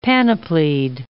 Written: panoplied

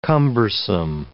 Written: cumbersome

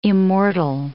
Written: immortal

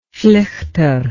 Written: flighter

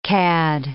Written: cad

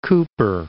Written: Cooper City

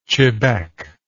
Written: chebec

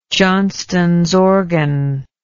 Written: Johnston's organ

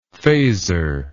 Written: phasor

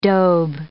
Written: Dobe

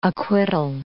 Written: acquittal

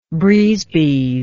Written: brise-bise

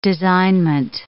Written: designment

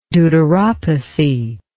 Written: deuteropathic

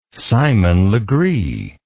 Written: simon legree

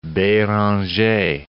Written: Beranger

